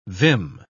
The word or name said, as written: vim